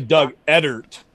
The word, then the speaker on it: Edert: Is that how you pronounce his name, Joe Edert? – It's actually Doug Edert.